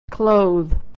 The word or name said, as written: clothe